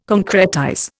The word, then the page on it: concretize: Indian